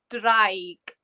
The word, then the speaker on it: draig: So dragon is pronounced as draig in Welsh.